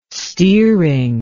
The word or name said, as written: steering